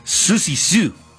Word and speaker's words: Siouxsie Sioux: Se pronuncia, bueno, perdóneme usted, Siouxsie Sioux.